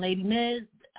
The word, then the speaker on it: LadiMiz: So if you just type in Lady Miz, I'll come up. I just always tell people, remember, I spell it with an I, not a Y. So it's LadiMiz.